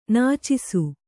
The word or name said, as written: ನಚ್ಚಿಸು